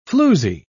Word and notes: floozie: British English

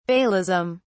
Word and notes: baalism: British English

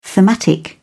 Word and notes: thematic: British English

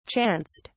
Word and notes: chanced: British English

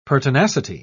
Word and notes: pertinacity: British English

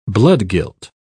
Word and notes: bloodguilt: British English

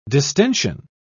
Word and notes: distention: British English

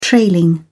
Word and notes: trailing: British English

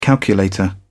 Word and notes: calculator: British English

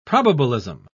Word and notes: probabilism: English; British English

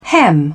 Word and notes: hem: British English

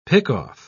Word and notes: pick-off: British English

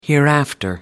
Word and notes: hereafter: American English

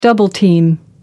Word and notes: double-team: American English

